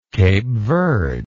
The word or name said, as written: Cape Verde